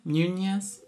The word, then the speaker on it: Muniz: Muniz? – Muniz.